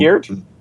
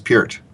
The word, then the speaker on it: Peart: Peart? – I think that's how it's pronounced, is Peart. – Not Peart? – That's what I've been told.